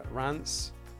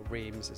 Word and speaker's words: Reims: I was in Reims, as the French pronounce it, or Reims, as everybody in the UK seems to pronounce it.